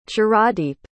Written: chiradeep

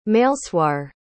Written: meilseoir

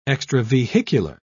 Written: extravehicular

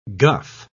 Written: guff

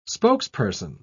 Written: spokesperson

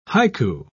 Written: haiku

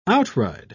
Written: outride

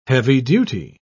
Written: heavy-duty

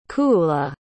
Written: cuuladh

